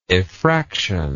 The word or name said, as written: effraction